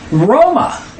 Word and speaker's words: Roma: Roma. It's two syllables. It's not R-O-M-E. It's R-O-M-A. And it's Rome hyphen A. Roma.